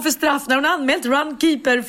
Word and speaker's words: Runkeeper: Hon tog fram sin mobil och visade och då insåg jag att Runkepär uttalas inte Runkepär utan Runkeeper.